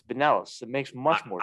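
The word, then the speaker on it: Binelas: Is it Benelis? Is that how it's pronounced? – Yeah, Alex Benelis. – Man, yeah, I thought it was Bynelos the entire time.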